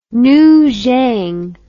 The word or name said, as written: Nujiang